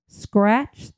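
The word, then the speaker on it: Skratch: Hammer, Noon, and that's spelled N-U-U-N. Salt Stick, Scratch, that's S-K-R-A-T-C-H. Tailwind, T-A-I-L-W-I-N-D. Ultima, and Martin. And Martin is spelled M-A-U-R-T-E-N.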